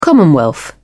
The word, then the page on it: commonwealth: British English